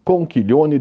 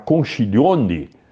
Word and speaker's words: conchiglione: Aliás, gente, quando vocês forem pedir essa massa, vocês têm que pedir exatamente como eu falei, é conchiglione, porque o CH em italiano faz que. Se você falar conchiglione, tá errado.